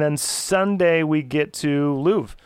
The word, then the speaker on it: Louvre: L O U V R E. – That's correct. – Yep. – Louvre.